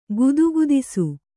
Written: ಗದಗದಿಸು

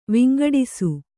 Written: ವಂಗಡಿಸು

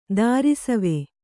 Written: ದಾರಿಸವೆ

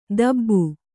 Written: ದಾಬು

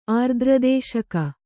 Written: ಆರ್ದ್ರದೇಶಕ

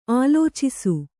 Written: ಆಲೋಚಿಸು

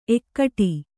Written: ಎಕ್ಕಡಿ

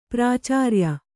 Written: ಪ್ರಾಚಾರ್ಯ

